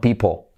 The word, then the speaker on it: people: Que fíjate que people lleva la letra clave L. No sería people, ni people, people, sino people. People. Esa L atrás casi ni se escucha.